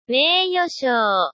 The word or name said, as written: meiyoshou